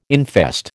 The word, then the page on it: infest: American English